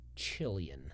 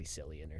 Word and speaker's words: Cillian: How do you say this one? – Chillion. – Chillion? – Nah. It's probably Cillian or something.